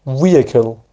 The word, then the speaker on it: vehicle: تلفظ درست این واژه هم که شاید شما هم vehicle اشتباه ممکن تلفظ کنید، vehicle، vehicle تلفظ درستشه، vehicle، اچش تلفظ نمیشه، vehicle، vehicle، vehicle.